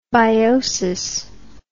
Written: biosis

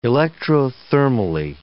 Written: electrothermally

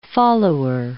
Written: follower

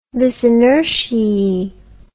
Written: vis inertiae

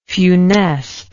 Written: funest

